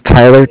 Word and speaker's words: Tyler: My name is Tyler, T-Y-L-E-R, and I'm calling from Bank of Cardiff. It's spelled C-A-R-D-I-F-F, as in Frank.